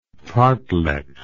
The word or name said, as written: fartlek